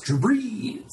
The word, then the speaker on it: Drew Brees: I believe that's pronounced Drew Brees.